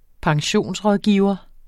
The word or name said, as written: pensionsrådgiver